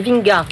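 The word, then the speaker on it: Vingegaard: Jonas Vinga, on dit Vinga. Hein voilà, La prononciation danoise n'est pas garantie.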